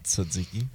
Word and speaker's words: tzatziki: Usually there's some tzatziki. How do you say that sauce? – Tzatziki.